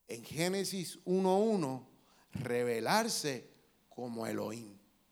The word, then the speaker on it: Elohim: Se dice Elohim.